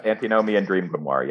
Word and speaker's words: antinomian dream grimoire: the book is described as an antinomian dream grimoire. Am I saying that correctly? Yeah. – Yeah, antinomian dream grimoire, yeah.